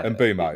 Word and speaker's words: Mbeumo: Mbeumo. – Yeah, Mbeumo, yeah.